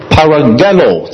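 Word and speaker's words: paragelo (παραγγέλλω): 吩咐这个词，请大家留意，原文希腊文，它读作 paragelo 这个词。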